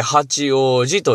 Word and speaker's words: 八王子: うなあところといえば、東京にあります八王子市なんです。僕の中でね。で、八王子市ってえらい決体な名前やなと、東京来た頃なんかは思った次第でございますが、あ確かに、八つの王子様と書いて八王子と読みますね。